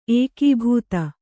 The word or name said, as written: ಏಕಾಬ್ದೆ